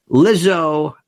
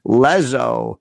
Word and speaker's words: Lizzo: And the Grammys are acknowledging it by telling you, enough with this Lizzo. I was pronouncing her Lezzo the first time.